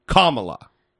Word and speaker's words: Kamala: She pronounces her name Kamala.